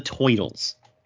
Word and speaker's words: toitle: He said it's because of the toitles. And that's how he pronounces it. It's written like that the entire book. T-O-I-T-L-E. Toitle.